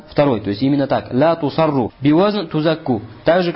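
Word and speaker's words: Ля тусарру (لا تصروا): Ля тасурру. Валь аввала сах. Но первое чтение является более правильным. Читается как именно «ля тусарру», а не «ля тасурру».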